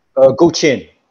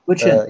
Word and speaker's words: Guqin: You actually played the ancient Chinese zither, or how's it pronounced? – Guqin. – Guqin.